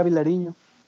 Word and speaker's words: Vilariño: Yo tampoco conocía a Vilariño, entonces, ¿sí lo dije bien? – Perdón. Sí, ya Vilariño.